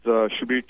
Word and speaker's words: Subir: If they just Google my name, is Subhar Chowdhury. It's spelled S-U-B-I-R.